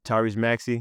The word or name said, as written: Tyrese Maxey